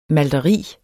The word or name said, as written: malteri